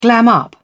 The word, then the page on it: glam: British English